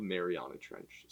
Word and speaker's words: Mariana: Yeah, like we've explored the Marina Trench. Mariana. – The Marina Trench. – Yeah. Update. – How do you say it? – We had a mishap last episode. It's called Mariana Trench, so.